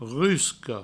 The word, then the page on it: rusca: rusco